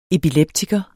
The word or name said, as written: EPILEPTIKERE